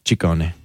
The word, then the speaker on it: Ciccone: Well, in Italian, it works the other way. So the double C's are hard C's and the singular C's are soft C's. – So Ciccione. – Ciccone.